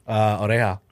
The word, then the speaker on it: oreja: Oreja.